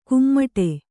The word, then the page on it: ಕುಮ್ಮಟೆ: kummaṭe